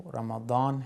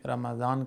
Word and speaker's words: رمضان: یہ جو کلمہ رمضان ہے، اصل اس کا جو تلفظ ہے عربی میں وہ رمضان ہے، اور ہم اس کو اردو میں رمضان کہتے ہیں۔ تو بہرحال اس رمضان کو رمضان کیوں کہا جاتا ہے؟